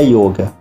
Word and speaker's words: yoga: Yoga ou yoga? As duas pronúncias são corretas. Na Índia se pronuncia yoga, mas aqui no Brasil existe a pronúncia também yoga. Aí você pode falar da forma que achar melhor.